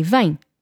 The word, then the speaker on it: vem: So let's ignore the accent for a second over the E and say vem. So that sound, em. Do not say M, em. Do not allow your lips to meet as you pronounce that M. It's just a nasal sound.